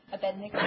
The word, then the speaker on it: Abednego: I can never say his name. – Abednego. – That's it.